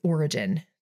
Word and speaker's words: Origen: The next person we're going to talk about is Origen. So this is spelled O-R-I-G-E-N, and he lived in Alexandria around AD 185 to 254.